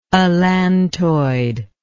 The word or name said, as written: allantoid